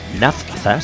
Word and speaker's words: Naphtha: Naphtha, se escribe N-A-P-H-T-H-A,